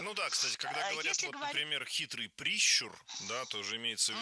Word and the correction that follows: прищур: Не прищур, а прищур. Ударение в этом слове ставим на второй слог. Да там его и оставляем во всех формах.